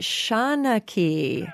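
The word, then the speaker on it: seanchaí: How's my favorite Shanaki? – That's exactly the right pronunciation.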